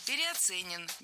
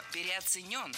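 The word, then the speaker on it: переоценён: Потому что труд медиков во время Великой Отечественной войны, он, конечно, переоценен быть не может. – Переоценен. Неверно. Ударение в этом слове следует ставить на последний слог, в котором должна быть наша любимая «ё». Переоценен.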